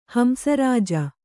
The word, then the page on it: ಹಂಸರಾಜ: hamsa rāja